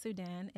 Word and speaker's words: SUDXN: My name is SUDXN. It's spelled S-U-D-X-N.